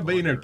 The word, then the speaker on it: Boehner: Pero el, apellido se escribía Boner. Que Boner es un huevo parado. – Oh. – Y el tipo, no, se pronuncia Boehner.